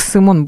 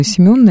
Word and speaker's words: Симеон Будный: Первый печатник у нас этот Симеон Будный, который, я не уверен, даже сейчас, кстати, говорю, что я по-русски его правильно произношу, потому что в белорусской школе его учили как Симон Будный. Симеон, наверное, правильно по-русски.